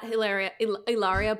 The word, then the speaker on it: Hilaria: If you want to see her doing yoga on the stove, you can catch her at at Hilaria Baldwin. H is silent.